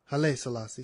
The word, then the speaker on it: Haile Selassie: Haile Selassie, sorry about mispronouncing his name.